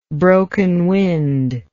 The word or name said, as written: broken wind